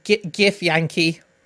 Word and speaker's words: Githyanki: followed by whatever the hell that is, gif Yankee. Well, it's spelled GITHYANKI.